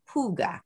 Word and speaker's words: hygge: And we also kind of talked, Tracy and I talked a little bit before the show about hygge.